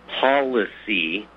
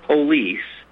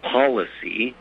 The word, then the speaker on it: policy: This is that administrative, this military concept, this policy, not police, three-syllable word, it's policy,